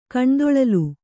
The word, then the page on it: ಕಣ್ದೊಳಲ್: Kannada; kaṇdoḷal